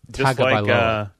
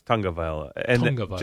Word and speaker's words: Tagovailoa: T-A-U-L-I-A. By the way, why do people say Tagovailoa? There's no N anywhere in there. – That's how he pronounced really? – It. – Do we have to go over weird pronunciations and spellings? – It's Tagovailoa. Tagovailoa.